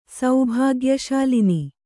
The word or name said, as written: ಸೌಭಾಗ್ಯಶಾಲಿನಿ